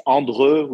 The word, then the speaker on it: Andreu: My family name is Andreu, which is a bit difficult to pronounce.